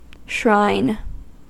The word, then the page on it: shrine: American English